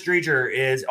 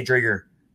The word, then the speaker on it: Driedger: But Chris Driedger only has 14 career NHL starts. So he's definitely – or Driedger. I should say Driedger. Correct pronunciation there.